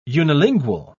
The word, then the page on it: unilingual: British English